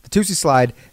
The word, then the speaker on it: Toosie Slide: But it's like I I was like, is it Tootsie or is it Tootsie? So I'm glad I said it right. Toosie Slide.